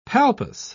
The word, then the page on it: palpus: British English